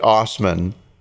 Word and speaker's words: Assman: His last name is Assman. He pronounces it Osman, right? – Osman. – A-U-S-M-A-N. But it is A-S-S-M-A-N.